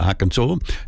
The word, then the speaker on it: Arkansas: Although it's spelled Arkansas, I'll go along with it, Arkansas.